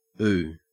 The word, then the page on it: ooh: Australian English